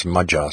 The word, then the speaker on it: Magyar: which I believe should more correctly be pronounced Magyar, so perhaps I should start doing that.